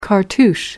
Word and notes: cartouche: American English

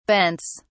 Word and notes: bents: British English

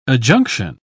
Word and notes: adjunction: British English

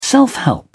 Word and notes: self-help: British English

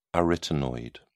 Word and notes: arytenoid: British English

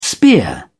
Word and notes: spear: British English

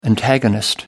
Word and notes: antagonist: American English

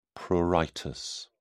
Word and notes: pruritus: British English

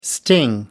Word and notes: sting: American English

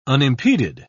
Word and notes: unimpeded: British English

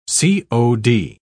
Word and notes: c.o.d.: British English

